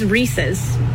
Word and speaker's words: Reese's: A pet peeve that I have that I'll never get over is people pronouncing it Reese's. It is Reese's. It is a guy named Reese and it is his. It's his. Reese's. And it's in the commercial. Reese's Puffs, Reese's Puffs. R-E-E-S-E-S. But it's Reese's. The company is not mispronouncing their own name. It's Reese's. And Reese's Pieces still rhymes.